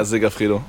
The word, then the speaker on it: Segafredo: Ja, Segafredo. – Segafredo.